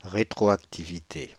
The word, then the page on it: rétroactivité: Paris; [ʁe.tʁɔ.ak.ti.vi.te]; /ʁe.tʁɔ.ak.ti.vi.te/